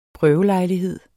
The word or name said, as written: prøvelejlighed